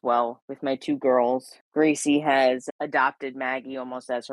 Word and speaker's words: Greycie: And the gray cat, her name is Gracie, and we spell it with G R E Y C I E. So it's Gracie, like her color because she's mm-hmm.